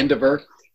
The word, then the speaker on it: Vandiver: Vandiver.